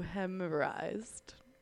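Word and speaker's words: euhemerised: You hemorrhized.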